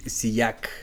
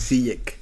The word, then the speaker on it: Ziyech: Que yo no sé si lo hemos dicho acá, pero originalmente es Hakim Siyak pero los holandeses no podían pronunciar Siyak y todo el mundo desde la escuela le dijeron Siyek Y el güey dijo, sí, ya chinga su madre, póngale Siyek Pues sí, pues sí.